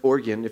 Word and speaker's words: Oregon: and it's pronounced Oregon if you're from the West Coast.